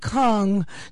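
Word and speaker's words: Cong: Yeah, and it's on the grounds of Ashford Castle, and the town in which it lies, which is called Kong, not spelled like King Kong with a K, spelled with a C, C-O-N-G.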